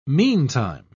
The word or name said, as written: meantime